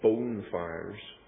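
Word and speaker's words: bonfire: Bonfires are really pronounced bone fires.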